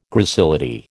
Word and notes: gracility: American English

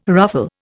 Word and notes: ruffle: Indian

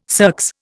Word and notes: circs: Indian

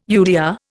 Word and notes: UDR: Indian

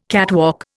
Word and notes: catwalk: Indian

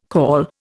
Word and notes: caul: Indian

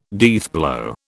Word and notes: deathblow: British English

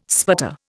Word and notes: sputter: Indian

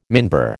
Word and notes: minbar: American English

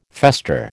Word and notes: fester: American English

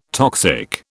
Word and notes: toxic: British English

